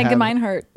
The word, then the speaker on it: Gemeinhardt: It's like Gibson, but Gemeinhardt.